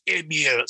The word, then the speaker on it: Emil: And so I met I know a Bulgarian guy, and he's like, you say your name wrong. It's Emil. So I'm like, whatever, bro. So that's just how we grew up saying it, Emil.